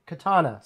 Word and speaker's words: Katana: Black Adam, The Rock, Rick Flag, The Joker, and Kataria. Katana. Sorry, Katana. There was something on my screen.